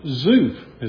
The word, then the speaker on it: Zuph: the son of Zuph is how that's pronounced.